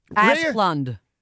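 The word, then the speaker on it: Asplundh: Asplund.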